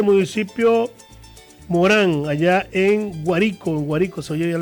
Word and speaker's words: Guárico: Y allá en Lara está, se escribe Guárico, pero se pronuncia Guárico, Guárico. Y en Guárico está la,